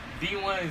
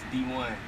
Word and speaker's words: Dewone: Well, alright, so D1 is really wordplay for my name. It's D1. Like the way you spell it is D-E-W-O-N-E. So you say it's D1.